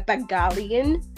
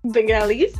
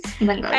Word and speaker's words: Bengalis: Just ways of greeting that would be helpful for someone who's going to Bangladesh and wants to say hello to a Bengalian. No, I don't think that's how you say it. – Bengalis, okay. – Yes, Bengalis.